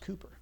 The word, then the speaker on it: Cowper: His name is spelled C-O-W-P-E-R, but it's pronounced Cooper.